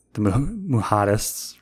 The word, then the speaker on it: Mujahideen: If I copy this word and go here and I paste it, how do you say this? – Mujahideen.